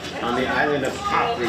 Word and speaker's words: Capri: Yes, Capri.